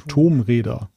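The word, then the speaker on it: Tomb Raider: Tomräder muss es ja dann heißen. Tomb ja, Raider. Ja.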